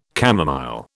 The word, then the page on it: camomile: British English